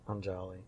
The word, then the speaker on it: Anjali: Anjali. A-N-J-A-L-I. – A-N with a wiggly? Anya? And then J-A-L-I.